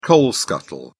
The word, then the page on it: coal scuttle: British English